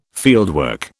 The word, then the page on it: fieldwork: British English